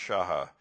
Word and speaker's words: shacha: The primary Old Testament word for worship, spelled S-H-A-C-H-A in English, I guess, is pronounced Shaha, and it means to bow down, to prostrate oneself, to do obeisance.